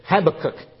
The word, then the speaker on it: Habakkuk: And old Habakkuk said, Habakkuk is how you say it.